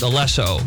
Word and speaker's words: Alesso: It's actually pronounced Alesso, and he's just the guy makes the beats.